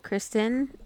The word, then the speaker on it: Kristyn: And Kristen is spelled K-R-I-S-T-Y-N.